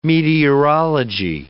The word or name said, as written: meteorology